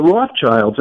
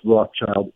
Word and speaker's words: Rothschild: So I heard from 2 independent sources, but I think both on PRN, that the Rothschilds, and it's not pronounced Rothschild, it's two German words, Red Shield, Rothschild.